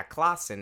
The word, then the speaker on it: Klassen: Klassen? Klassen, you say? – Yeah, Klassen. It's got German descent. – Oh, so I've been pronouncing it wrong.